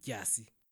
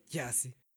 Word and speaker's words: Dyasi: How they're supposed to spell it is D-Y-A-S-I. Yasi. – Yeah. – That's Xhosa, right? Yasi.